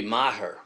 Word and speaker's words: Maher: But the whole situation gets very confusing when you have a dimwit leftist, ultra-leftist, like Bill, how does he pronounce his name? Maher? Mayer? – Mayer? Bill Mayer? I don't know. – It should be Maher. – Maher. – Yeah, he's a Mick.